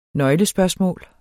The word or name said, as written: nøglespørgsmål